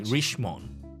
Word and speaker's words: Richemont: But again, it, it, we are supposed to pronounce it French. – So this one is supposed to be Richemont. Richemont. Yeah, it's it's supposed to be like that.